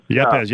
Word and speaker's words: Yepez: How do you say his last name? Is it Juan Yepez? – Juan Yepez? – Yepez, yeah. So, I mean, I know he's got a lot of upside.